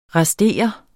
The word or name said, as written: restere